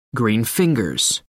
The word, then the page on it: green fingers: American English